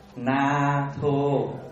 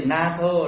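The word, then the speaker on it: nātho: Na tho là viết cái chữ N A dấu ngang trên đầu, thô là T H O, đọc là Na Thô. Na Thô. Đánh vần chữ Na Thô là N A ngang trên đầu, T H O, đọc là Tham Mô Mê Na Thô.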